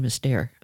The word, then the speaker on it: Esther: Yeah, she pronounces her first name Esther.